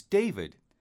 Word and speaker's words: David: J-E-S-S-I-C-A. – Okay, J-E-S-S-I-C-A. – Yes, that's right. What's your name? – My name's David. – Can you spell that, please? – Of course. D-A-V-I-D.